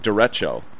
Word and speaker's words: derecho: But by the way, I believe it's pronounced derecho.